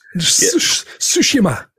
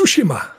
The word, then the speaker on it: Tsushima: I did talk yesterday to the some actor that played in some game called The Ghost of Shinami. – Tsushima. It's pronounced Tsushima.